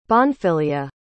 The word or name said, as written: Bonfilia